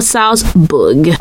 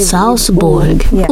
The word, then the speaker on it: Salzburg: Is it Salzburg or Salzburg? – Initially, before I came here, I was also pronouncing it as Salzburg. – Uh-huh. – When I came here, I think the dialect pronounces it as Salzburg. – Oh, maybe. Salzburg. Ooh, it's an ooh.